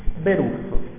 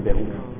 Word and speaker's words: Beruf: Beruf, tale quale. Si pronuncia con l'accento sulla U, quindi Beruf. – La B? – B-E-R-U-F.